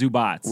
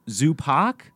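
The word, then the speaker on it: Zubac: I'm pretty sure, according to Basketball Reference, it's, it's Zubats.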